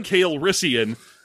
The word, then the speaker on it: Calrissian: Calrissian. So Lando corrects him, like, flat in the first A, and also it's the accent on the second syllable of the name.